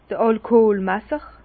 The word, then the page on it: d'r Alkoolmesser: Bas Rhin; Herrlisheim